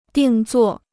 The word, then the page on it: 定做: dìng zuò